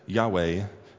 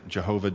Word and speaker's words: Yahweh: So we pronounce it Yahweh. Others pronounce it Jehovah, depending on, in fact, how you translate the letters and the pronunciation.